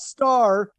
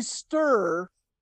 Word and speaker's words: Sturr: 87 Grammy Award winner, Jimmy Sturr. – Yes! – Not star, Jimmy Sturr and his orchestra.